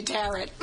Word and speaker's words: tarot: We'll let you live if you say tarot. – One of the rhymes with carrot.